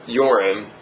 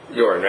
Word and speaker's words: Euron: This goes into what I was kind of saying before about um, I keep calling him Euron. Yorin. Yorin, right? Yeah. That's how you say it.